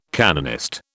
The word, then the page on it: canonist: British English